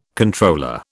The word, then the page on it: Comptroller: British English